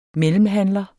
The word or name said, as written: MELLEMHANDLERENS